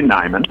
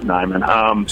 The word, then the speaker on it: Niman: Yeah, first, and this happens often, the first thing I'll need to do is correct pronunciation. It's actually Neiman.